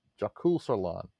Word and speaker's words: Jökulsárlón: I'm going to say it very poorly because this is in Iceland, but the Jökulsárlón, I can't even say the letters, Jökulsárlón, Jökulsárlón.